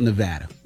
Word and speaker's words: Nevada: People always say that's amazing, but how does that help Nevada? Did I say it right? – Mm-hmm. Yeah, you said it right. – How does it help Nevada? – Yeah.